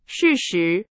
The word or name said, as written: 事实